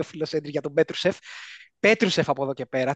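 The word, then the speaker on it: Petrušev: Μάθε να τον λες. Πετρούσεφ.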